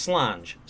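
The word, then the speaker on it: Sláinte: Slancha is pronounced Slange. Slange. Same in Irish Gaelic and Scots Gaelic.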